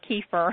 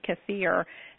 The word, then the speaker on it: kefir: Now we pronounce it in the South, water kefir, okay. – But I have been trying to retrain my family and say the proper pronunciation is actually kefir. My little toddler corrected her daddy this week and said, "Daddy, it's not water kefir, it's kefir."